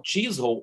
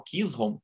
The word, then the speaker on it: Chisholm: Aliás, esse processo, né, o Chisholm ou Kisholm, conforme a pronúncia, eh, versus Georgia, é considerado como o primeiro caso importante da Suprema Corte.